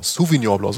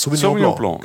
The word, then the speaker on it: Sauvignon Blanc: Da gibt es natürlich auch sowas wie Riesling oder Pinot Blanc, Sauvignon Blanc, so spricht man das aus. – Sauvignon Blanc.